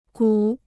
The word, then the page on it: 鼓: gǔ